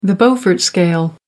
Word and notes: beaufort scale: American English

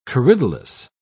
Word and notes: corydalis: British English

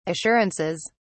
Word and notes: assurances: British English

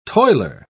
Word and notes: toiler: British English

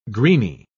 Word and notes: greeny: British English

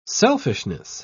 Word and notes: selfishness: British English

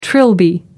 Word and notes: trilby: American English

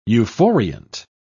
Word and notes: euphoriant: British English

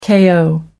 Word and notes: ko: American English

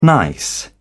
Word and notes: nice: British English

